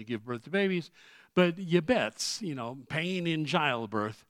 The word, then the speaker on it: Jabez: Now, of course, in Hebrew, that's not pronounced Jabez. They don't have a J in their alphabet. You know how his name's pronounced? Yabetz. Yabet. It's like, well, do you think it's going to be bright and sunny tomorrow? Yabet. Well, so Yabetz, that's his name in Hebrew.